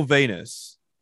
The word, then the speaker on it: Venus: I mean, Michael Venus name rhymes with penis, and I can't stress that enough.